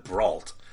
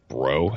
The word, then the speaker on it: Brault: Is it Mike Bro or Brault? I've always said Brault. I've worked with a guy that was Brault. – I think that's the English pronunciation of it, because it's like Terrio. There's no like TH sound in French, so I think it's like Bro. I think it's how you technically say it.